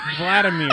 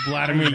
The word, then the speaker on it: Vladimir: Vladimir, not Vladimir, you putz.